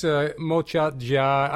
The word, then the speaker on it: Mozartjahr: You were invited to write a piano concerto for the, you pronounce it, Wiener Mozartjahr? – Yeah, I just, Mozartjahr, I give it, it's just the Mozart year.